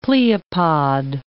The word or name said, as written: pleopod